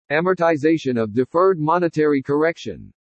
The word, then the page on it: AMORTIZATION OF DEFERRED MONETARY CORRECTION: english